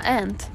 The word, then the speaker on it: aunt: För när man, det är också typ en liten gråzon för hur man uttalar ordet muster på engelska. – Ja, aunt. – Aunt. – Aunt. – Men det säger du på riktigt ant.